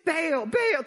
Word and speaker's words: Baal: Baal, tear down the Baal, tear down Baal.